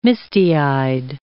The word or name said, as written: misty-eyed